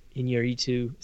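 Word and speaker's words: Iñárritu: We can't all be, you know, Inarritu.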